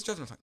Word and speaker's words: Munthali: The Travis H is silent. – Munthali. – Yes. – Travis Munthali. You love that fact. – Mm-hmm. – That's you always say your name so fast. – Yes. – I never say it wrong. You're like, Travis Munthali. – It's Travis Munthali. – Travis Munthali. – No H.